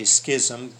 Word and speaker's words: schism: I believe the pronunciation should be schism because it's got that S-C-H like in school.